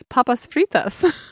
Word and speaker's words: papas fritas: And I was like, and also palmas fritas? Except that's not how you say it. It's papas fritas.